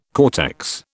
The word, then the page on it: cortex: British English